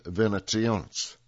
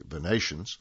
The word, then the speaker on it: venationes: Through dozens of trap doors in the arena floor, handlers could release animals directly into the ring for staged hunts called venationes that typically served as the appetizer for gladiator fights. And that word can also be pronounced, and should be pronounced perhaps more correctly, venations.